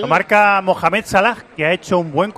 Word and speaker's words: Salah: Qué bien has pronunciado, Salah. – Salah.